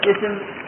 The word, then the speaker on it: اسم: سبح اسم ربك، هل نطقنا بالألف في اسم؟ ها؟ ما نطقنا، فوصلنا آخر كلمة في سبح وهو الحاء بالسين. بالسين سبح اسم ربك، فما نطقنا بماذا؟ بالألف، ما نطقنا لأنها وصل، تصل ما قبلها فيما بعدها، فلهذا سميت وصل، وهي تكتب ولا تنطق.